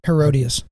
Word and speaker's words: Herodias: And did you say Herodias? – Herodias.